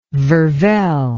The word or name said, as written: vervelle